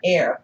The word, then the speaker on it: Eir: Yeah, so air is one of Frigga's handmaidens. – Mm-hmm. – She has several, and modern... – And that is spelled E-I-R. – E-I-R, yes.